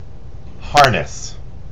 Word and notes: harness: American English

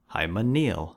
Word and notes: hymeneal: American English

